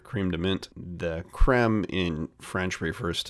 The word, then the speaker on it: crème de menthe: Montpastil is the original crème de menthe, or crème de menthe, if you pronounce it the French way.